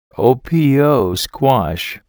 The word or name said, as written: opo squash